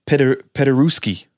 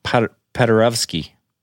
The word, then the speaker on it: Paderewski: Ignacy Paderewski. Yeah, I don't know. Sometimes the W's right before a ski makes a V sound. Like I know in Polish it does. So and maybe it's Paderewski.